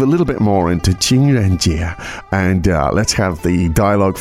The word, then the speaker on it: 情人节: 情人节.